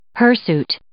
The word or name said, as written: hirsute